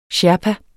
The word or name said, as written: sherpa